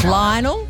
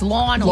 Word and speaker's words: Lionel: Well, apparently I'm a wanker for saying it that way. It's Lionel. – It's Lionel. – Lionel.